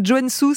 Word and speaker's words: Joensuu: Merci Gauthier Tassori, en direct donc j'essaye de bien le prononcer, Joensuu, c'est ça ? – C'est ça.